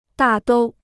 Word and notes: 大都: dà dōu

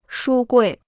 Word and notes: 书柜: shū guì

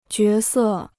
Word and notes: 角色: jué sè

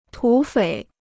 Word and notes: 土匪: tǔ fěi

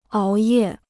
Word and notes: 熬夜: áo yè

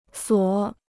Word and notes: 锁: suǒ

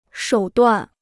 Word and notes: 手段: shǒu duàn

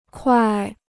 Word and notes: 块: kuài

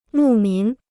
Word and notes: 牧民: mù mín

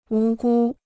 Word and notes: 无辜: wú gū

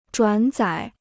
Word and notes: 转载: zhuǎn zǎi

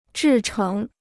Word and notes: 制成: zhì chéng

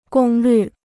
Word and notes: 功率: gōng lǜ

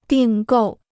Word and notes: 订购: dìng gòu

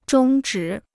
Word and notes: 中止: zhōng zhǐ

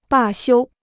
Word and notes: 罢休: bà xiū

